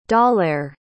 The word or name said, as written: dalair